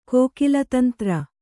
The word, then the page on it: ಕೋಕಿಲತಂತ್ರ: kōkila tantra